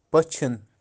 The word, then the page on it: Pachin: Kashmiri